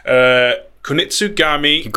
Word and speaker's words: Kunitsugami: It is, I'm going to butcher this pronunciation, not intentionally, Kunitsugami Path of the Goddess. – Yeah, that's it.